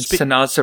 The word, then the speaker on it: Sanaa: S-A-N-A-A. – It's Sana. – Sana, okay. Which is a dark roast blend. It's Joffrey's. So there you go. – Very cool. Thank you, Mary Jo. – And Sana's a restaurant at Disney's Animal Kingdom Lodge.